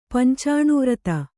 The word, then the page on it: ಪಂಚಾಣುವ್ರತ: pancāṇu vrata